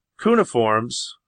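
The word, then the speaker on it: cuneiform: cuneiforms, yeah, that's how you say it, tablets from Iraq into the United States via Israel, labeling them tiles.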